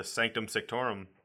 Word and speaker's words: Sanctum Sanctorum: I said that wrong, I think. – You said it weird, but you said it right. – Okay. – you hit all the letters, but you said like you were unsure of yourself, so you're like, Sanctum Sanctorum.